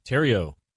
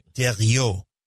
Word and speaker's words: Thériault: Thériault. French-Canadian. That's right. – Thériault.